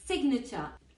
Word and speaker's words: signature: G in sign must be silent. La G en sign es muda, sign. Pero in signature pronounce G. En firma pronuncias la G, como en ignorant, signature.